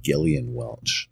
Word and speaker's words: Gillian Welch: Her name is actually, it's pronounced Gillian Welch.